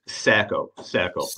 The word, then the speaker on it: Sacco: Sacco. Like Sacco quarterback.